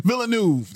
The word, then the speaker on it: Villeneuve: Villanova. Villeneuve. That's what it is.